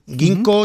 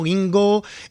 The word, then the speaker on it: ginkgo: Ginkgo, gingo, vamos a ver luego cómo se le llama, porque le podemos llamar de muchas maneras, porque es una planta que se escribe con una K entre medias y bueno, pues hace un poquito de confusión. – ¿Tú cómo la llamas? – Yo le digo ginkgo.